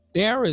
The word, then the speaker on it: Darrell: And for everybody out there, Dara is not spelled Mines. It's not spelled D-A-R-Y-L. It is D-A-R-R-E-L-L. And Jaye is spelled out as J-A-Y-E.